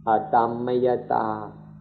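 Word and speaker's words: atammayatā: So please be patient and learn the Pali word adhammayata. Adhammayata, A-T-A-M-M-A-Y-A-T, long A, adhammayata.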